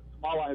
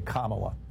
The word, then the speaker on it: Kamala: So say this word, comma. – Comma. – Okay, now say Kamala.